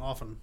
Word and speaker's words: often: You keep saying T and often. – Often? – Yeah, you keep saying that. – It's got a T in it.